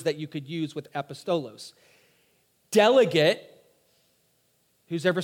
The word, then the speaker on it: apostolos: Apostolos, A-P-O-S-T-O-L-O-S, A-P-O-S-T-O-L-O-S, apostolos or apostolos. I've heard it pronounced a couple different ways.